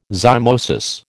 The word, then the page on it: zymosis: American English